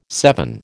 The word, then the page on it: seven: American English